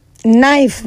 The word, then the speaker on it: knife: Knife. – Ní sọ pé knife. Àbí? A mọ̀ pé K ni spelling ẹ̀ ti bẹ̀rẹ̀ ṣùgbọ́n pronunciation là ń bá ṣe.